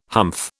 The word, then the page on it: humph: British English